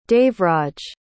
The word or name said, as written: devraj